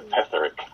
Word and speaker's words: Petherick: It's Cornish, and I normally say it's pronounced as it looks, but that's a little confusing. So Petherick.